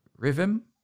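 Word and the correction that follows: RIVM: Arifem. Separately. Arifem.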